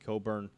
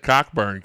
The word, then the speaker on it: Cockburn: Do you know, not to interrupt you, but I had no idea that's how you pronounce his name the other day, and I called Steve up. I was like, what about that Cockburn kid?